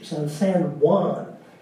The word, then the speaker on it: San Juan: It comes from Spanish origins, and in Spanish, the J's are pronounced with an H, San Juan.